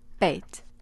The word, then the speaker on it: bayt: It employs a word that's spelled B-A-Y-T. – Bait. It's home. But also in Arabic, it means house, it means family, it means all of the above. To Anthony, finding this place or this home or this bait was everything, where he imagined his kids to be one day, where he found peace.